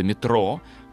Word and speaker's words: метро: А, и вот там уже слово метрополитен становится нарицательным нарицательным, а позже для простоты парижане сокращают его до метро, поэтому ударение именно на последний слог.